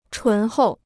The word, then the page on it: 醇厚: chún hòu